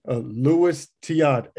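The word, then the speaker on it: Luis Tiant: Louis Tiant, aka El Tiante. Did I say that right? – Yeah, it sounds right.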